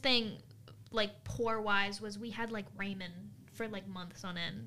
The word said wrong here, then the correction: ramen: The way you pronounce that's so interesting. – I was like, who's Raymond? That was like Raymond. – Ramen. That's very interesting.